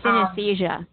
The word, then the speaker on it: synesthesia: So synesthesia.